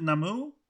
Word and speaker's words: Namou: How do you say your last name? Namou. Oh, you said it perfectly.